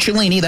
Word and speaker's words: Cellini: F-L-I-F-F. Get that app. Use the promo code Cellini. That's my last name, and it's spelled C-E-L-L-I-N-I to get started.